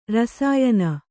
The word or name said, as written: ರಸಾಯನ